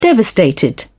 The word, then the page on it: devastated: British English